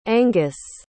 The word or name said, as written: anghus